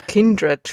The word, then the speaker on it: Kindred: Kindred spricht man das. – Kindred? Echt? Ist mein Leben lang falsch ausgesprochen.